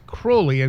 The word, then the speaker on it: Crowley: and for everyone who knows, it is Crowley, and Crowley used to say rhymes with holy. – That's right. Yeah. In fact, he wrote a poem where he rhymed it with holy, and that's how we know.